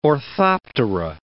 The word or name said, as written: orthoptera